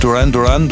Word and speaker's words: Duran Duran: Ja, nächste Band, die kann man eigentlich fast so aussprechen, wie sie geschrieben wird. Duran Duran, Duran Duran, das ist Deutsch-Englisch immer das Gleiche.